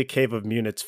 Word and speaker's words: Cave of Munits: We just bumped into each other just casually, and it was Cave of Munits, right? That's how you pronounce it? – Yes, it was definitely Cave of Munits for sure.